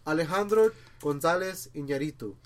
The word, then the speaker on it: Alejandro González Iñárritu: Okay, okay. Alejandro González Iñárritu. That's how you pronounce his name.